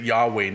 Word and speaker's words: Yahweh: Scholars like to pronounce it Yahweh now for some reason.